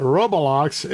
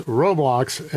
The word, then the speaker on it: Roblox: And by the way, pardon me, it's not Roblox, it's Roblox. And so I want to correct my pronunciation.